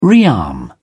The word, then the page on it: rearm: British English